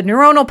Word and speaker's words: neuronal: So, when you're trying to create a new habit and sort of disassemble that old habit, that old myelinated neuronic, neur, neur, how do you say that? The, the neuronal path, ooh, there we go, neuronal path that has been established is still there.